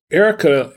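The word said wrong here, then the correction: Ercole: it's pronounced Ericole, is a good wine.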